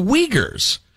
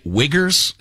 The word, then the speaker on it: Uyghurs: And he was talking about the Uyghurs although he kept pronouncing it Wiggers, and it really made me mm.